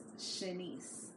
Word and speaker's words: Shanice: Bro, her name is Shanice, which rhymes with Lanice.